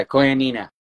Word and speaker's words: Koinonia: And I believe the way you pronounce this website is Koinonia House? – Yeah, Koinonia.